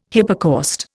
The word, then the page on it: hypocaust: Indian